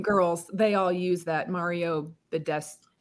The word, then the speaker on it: Badescu: And, I found the Mario Badescu.